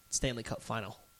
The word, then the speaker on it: Stanley Cup Final: Make sure we pronounce it correctly. He said a lot of people do it, but it's a pet peeve. Stanley Cup Final. There is no S.